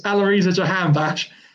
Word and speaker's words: Jahanbakhsh: Aloisa Jahanbakhsh, is it? – That's pretty close. It's Aloisa Jahanbakhsh.